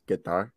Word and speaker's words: Qatar: You're saying it like a, to- a Texas oil man. It's actually Qatar. So yeah, if you want to be uh, cultured, it's Qatar.